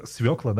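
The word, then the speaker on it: свёкла: это свекла, да, правильно говорить? – Свекла, правильно, свекла.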